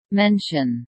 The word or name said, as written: mention